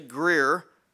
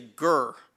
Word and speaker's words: Greer: And if you're from the South, you don't say Greer, you say Gurr.